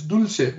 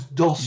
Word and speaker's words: Dulce: The Spanish pronunciation is Dulce, which means sweet. – Yes. – But I think the Americanization and the more, familiar term around the world is Dulce.